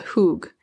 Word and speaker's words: hygge: And I don't know if I'm pronouncing it correctly, but there's a Danish concept called hug, where it's pronounced H-Y-G-G-E. It's written H-Y-G-G-E, I think, but it's pronounced something closer more to like hug.